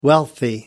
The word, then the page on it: wealthy: American English